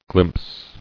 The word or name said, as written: Glimpse